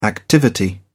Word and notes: activity: British English

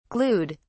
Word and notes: glued: British English

